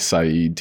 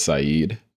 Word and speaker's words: Saidu: My first name is spelled S-A-I-D-U. And yeah, people are always thrown off by the U when they hear that it's Saeed.